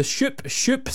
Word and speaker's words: Shoop Shoop: The Soup, not the Soup, the Shoop Shoop Song is on the way after a news update with Andrew Baxter next.